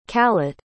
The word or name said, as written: kalat